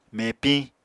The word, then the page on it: mepiñ: Apatani